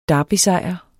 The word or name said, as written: derbysejr